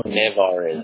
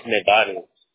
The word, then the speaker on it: Nevarez: My last name is spelled with a V, not a B. – Oh. – Nevarez. – Nevarez. Nevarez. What did I do? – It's Nevarez.